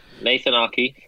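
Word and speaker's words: Aké: Now, Man City, who finished in second place, they did add a center back, Nathan Aki, right? Is that how you pronounce his name? Correct, yeah. Nathan Aki, former Chelsea player.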